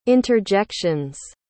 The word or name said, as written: interjections